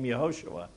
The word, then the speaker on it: Yehoshua: Yehoshua. It's pronounced Yehoshua. And that comes from a website that has Jewish people pronouncing it, not my horrible North Canton accent, okay?